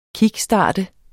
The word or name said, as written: KICKSTART